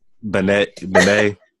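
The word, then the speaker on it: Benét: Benet.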